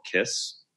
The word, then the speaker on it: Kysse: One new filler that just came on the market is by Restylane and is called Kiss. It's spelled KYSSE, and it's a new lip filler.